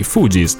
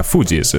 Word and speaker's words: Fugees: anzi i Fugees, tra l'altro mi sono accorto da poco che ho sbagliato la pronuncia per tutto il tempo della puntata, Fugees, non Fugees, e hanno inserito anche No Woman No Cry all'interno di questa produzione, di questo album.